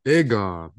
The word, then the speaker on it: Aegon: I've been saying his name wrong. It's Aegon, not Aegon.